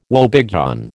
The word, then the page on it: woebegone: American English